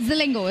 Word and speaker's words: Zilingo: Zilingo.